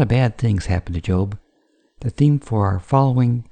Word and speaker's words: Job: Looking ahead, in our next lesson we will visit a character called Job, pronounced with a long vowel.